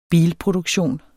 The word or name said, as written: bilproduktion